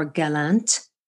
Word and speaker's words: Gallant: Today, I am speaking with Wade Lightheart and Matt Gallant, or Gallant, G-A-L-L-A-N-T, but French-Canadian, so we're going to pronounce it correctly.